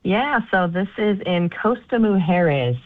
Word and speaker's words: Costa Mujeres: Costa Mujeres.